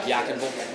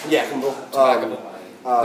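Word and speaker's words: Yadkinville: Like Yakinville. – Yakinville. – There's no Ville, it's Vol.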